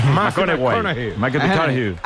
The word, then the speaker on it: McConaughey: ¿Matthew McConaughey? – Sí. Rico, sí. – Hay que ir corrigiendo la pronunciación. – McConaughey.